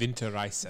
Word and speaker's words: Winterreise: I hope I said that right. – Winterreise. – Winterreise. Thank you.